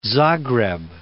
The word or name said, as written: Zagreb